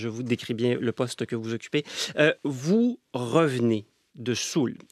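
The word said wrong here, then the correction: Suhl: Soul, ça, est-ce que je prononce bien? – Zoul, oui.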